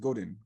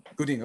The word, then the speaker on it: Godin: Now, did I say that right? Godin, or is it Godin? – Godin. – Okay, good. I said it right.